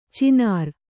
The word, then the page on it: ಚಿನಾರ್: cinār